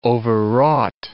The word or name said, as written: overwrought